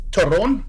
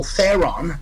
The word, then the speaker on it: Theron: Its claim to fame is the actress Charlize Theron, how it's pronounced in Afrikaans, or Theron, they would say in Hollywood.